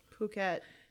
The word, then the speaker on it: Phuket: It was a full moon night on the island of Phuket.